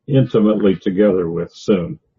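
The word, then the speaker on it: syn: But it comes out like S-O-O-N, by the way you say it, not sun. But to transliterate it comes out with a long U sound.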